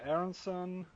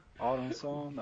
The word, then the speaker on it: Aronsson: Yeah, that was a new one, but yeah, Aronsson, Aronsson, that's a Swedish pronunciation.